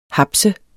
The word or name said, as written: hapse